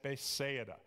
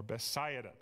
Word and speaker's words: Bethsaida: So the meeting takes place at Bethsaida, or Bethsaida, depending on how you want to pronounce it.